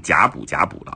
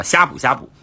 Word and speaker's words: 呷哺呷哺: 另外还有一家叫做呷哺呷哺的小火锅，同样很出名啊。大家读的时候可能会读错，因为它是一个甲，一个哺乳的哺，很多人可能就是口字旁一个甲，可能就读成甲哺甲哺了啊。其实叫呷哺呷哺啊。